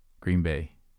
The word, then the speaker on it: Green Bay: It's more of an emphasis on the Bay. – Green Bay. – Green Bay.